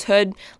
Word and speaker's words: Hood: People always say my last name wrong, and I've kind of stopped correcting them, but it is Hood, like the hood of a jacket.